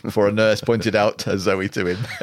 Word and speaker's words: Zowie: According to Nicholas Pegg's The Complete David Bowie, Zoe is pronounced like the girl's name, Zoe, intended as a masculine version of the Greek word for life.